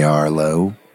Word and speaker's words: Arlo: You could just call me Arlo.